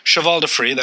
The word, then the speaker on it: cheval de frise: Cheval de frise, that's the singular pronunciation, were an anti-cavalry defense during the Middle Ages.